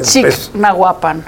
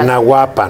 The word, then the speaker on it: Chignahuapan: Chignahuapan, ¿qué tal? – Chignahuapan, ¿no? Tal? No el rancho del aldeano. Chignahuapan.